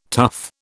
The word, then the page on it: tough: British English